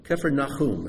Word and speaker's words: Kephar-Nahum: And Capernaum, Kephar-Nahum is how it's pronounced.